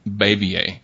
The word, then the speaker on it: Bavier: I believe it's pronounced Bav- Bavier.